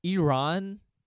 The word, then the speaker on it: Iran: It's like, dude, Iran is, that's not an American accent. Iran isn't an American accent. You're just saying it wrong.